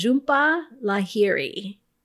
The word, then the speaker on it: Jhumpa Lahiri: And the author of this book was Zsumpa Lahiri. And I do believe that's how you say it because I listened to the audio version of this book and this is how the narrator said the name.